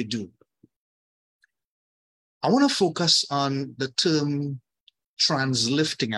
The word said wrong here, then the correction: translational lift: Am I pronouncing it correctly? – Translational lift. – Translational lift.